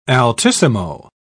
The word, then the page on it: altissimo: British English